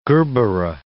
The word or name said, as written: gerbera